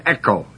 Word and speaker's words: echo: Hold fast is the word echo, E-C-H-O, long O.